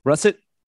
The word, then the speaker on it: russet: He was Russert. I always say that wrong. Russert potatoes. – Russet. Yeah.